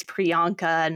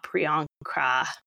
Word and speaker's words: Priyanka: Mackenzie rang in and said, who is Priyanka Chopra Jonas? And that is so many letters to get right. She added an extra R in there. It is Priyanka, not Priyanka.